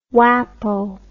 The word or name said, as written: whapo